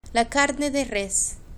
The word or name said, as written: la carne de res